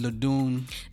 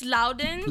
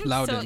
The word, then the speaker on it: Loudoun: How do you pronounce it? Ladoon? – It's Loudoun. – Loudoun.